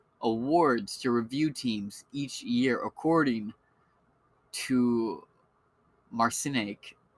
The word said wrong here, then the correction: Marciniak: M-A-R-C-I-N-I-A-K. Let's see. I'm not sure how to pronounce this name. Well, he's very accredited. – Marciniak. – No, that's right. That was pronounced.